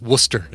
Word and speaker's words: Worcester: You don't even pronounce the R. It's Worcester.